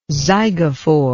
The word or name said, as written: zygophore